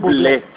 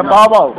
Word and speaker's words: Bublé: Yo sé hablar muy bien inglés y se pronuncia Bubble. – No, porque es francés y le ponen un acento al final.